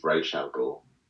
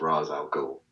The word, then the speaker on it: Ra's al Ghul: Even though the cartoon is Ra's al Ghul, to me it's Ra's al Ghul because it's R-A-apostrophe-S.